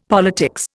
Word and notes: politics: Indian

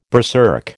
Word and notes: berserk: American English

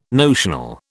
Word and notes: notional: British English

